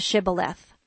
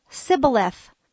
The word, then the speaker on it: Shibboleth: So the Gileadites probably said to the person, ask permission to cross over the river, the Shibboleth. The Ephraimites could only say Sibboleth.